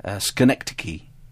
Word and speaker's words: Schenectady: Schenectady.